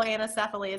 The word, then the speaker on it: anencephaly: like fetal anencephaly, is that, am I saying that right? – Yes.